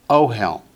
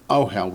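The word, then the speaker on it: ohel (אֹהֶל): Notice it is a masculine word, and the Hebrew word is pronounced ohel. Accent on the first syllable, ohel.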